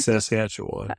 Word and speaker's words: Saskatchewan: I know I butchered that. – I think it's Saskatchewan.